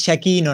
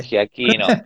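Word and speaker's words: Giacchino: giachino sería. – Eh, sí, creo que es Giaquino en realidad, pero sí, vos dale. – Giaquino, claro, pues es CH, sí.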